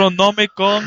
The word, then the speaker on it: Necronomicon: Er is een bepaalde scène waarin hij een mortuarium moet gaan zoeken naar de Necromonicon. – En dat kan je dus ook al niet uitspreken. Jij en jouw belachelijke... – Necronomicon. – Dat toch nog, ja.